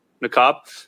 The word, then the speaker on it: Niqab: Niqab, is that how you pronounce that? – Niqab.